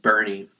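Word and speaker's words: Boerne: I grew up in the hill country of Texas, a little bitty town called Bernie. It was about Bernie, like B-E-R, like Bernie Sanders, Bernie? – No, but that's how it's pronounced. It's German. Okay. It's B-O-E-R-N-E.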